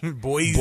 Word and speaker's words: Boise: Boise. – Boise. Got to hit the Z.